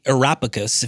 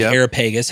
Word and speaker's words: Areopagus: He's at the Areopagus, if you call it that, or the Areopagus, however you want to pronounce it.